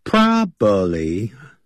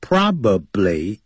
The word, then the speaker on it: probably: Probably 这样念的不对的，叫 probably 啊，probably 这样念，P R O B A B L Y， probably 可能会昏倒